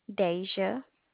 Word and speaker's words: Deja: Deja.